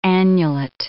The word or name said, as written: annulate